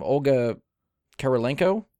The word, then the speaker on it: Olga Kurylenko: Olga Kurylenko? – No, you did good.